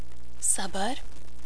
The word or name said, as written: Sabar